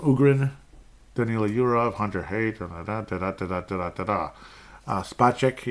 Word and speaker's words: Spacek: He's Space K, you know, like Space X, he's Space K, okay? That's how you pronounce it.